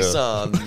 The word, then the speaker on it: Nissan: Nissan, apparently. Yeah, well, to Americans, they're Nissan. Yeah, Nissan. Yeah. Nissan, you know. But Nissan is how we say it in Australia.